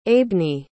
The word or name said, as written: aibne